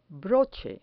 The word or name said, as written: broche